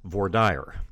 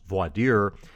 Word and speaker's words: voir dire: is called voir dire. That's the Texas pronunciation of a phrase that is more commonly pronounced voir dire in the rest of the country.